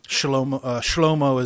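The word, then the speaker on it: Shlomo (שלמה): Shlomo is how you say Solomon in Hebrew.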